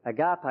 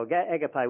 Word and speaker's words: agape: One of them was a word agape or agape, whichever way you want to pronounce it, a Greek word there.